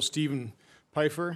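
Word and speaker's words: Steven Pifer: Our final witness is the Honorable Stephen Pfeiffer.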